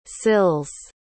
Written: sills

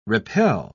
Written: repel

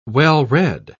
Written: well-read

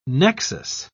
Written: nexus

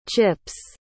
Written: chips

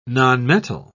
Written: nonmetal

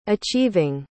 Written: achieving

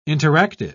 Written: interactive

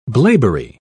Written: blaeberry

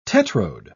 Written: tetrode

